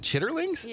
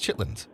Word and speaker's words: chitterlings: Is it chitterlings? – Yeah. – I thought it was chitlins. – That's probably how they say it in the South.